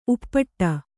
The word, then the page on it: ಉಪ್ಪಟ್ಟ: kannada